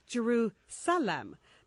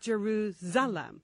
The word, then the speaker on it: Jerusalem: So FG says, Girls, love the show, love all the satellite shtick, love everything you do, but can't take the way you pronounce Jerusalem. Please see that it's an S, not a Z, hence Jerusalem, not Jeruzalem.